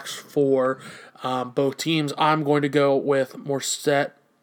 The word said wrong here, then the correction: Mostert: Mostert, excuse me. I say his name wrong all the time. It's Mostert.